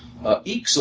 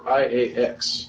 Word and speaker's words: IAX: Eeks, on the other hand, or IAX. – Thank you. – For some. – Some people who don't know, because it doesn't look like the way it's pronounced.